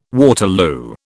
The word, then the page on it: Waterloo: British English